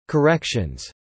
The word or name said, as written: corrections